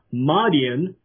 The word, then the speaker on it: Madyan: I would say the theory really starts with locating where Midian is, but specifically the city of, you could pronounce it as Madian, M-A-D-Y-A-N, because historical sources going back thousands of years had said that Mount Sinai was near this place called Madian, which in Saudi Arabia today is called Al-Bad.